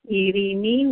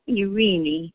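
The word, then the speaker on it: Eirini: Yes, it's E-I-R-I-N-I, and it's pronounced Irini with a rolling R, or Irini, and it means peace. In English, it's Irene.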